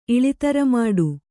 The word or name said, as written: ಇಳಿತರಮಾಡು